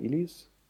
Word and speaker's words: Illies: Und ich habe die letzten, weil jetzt so eine Fortsetzung rauskam, fand ich sehr gut, 1913 von Florian Illies.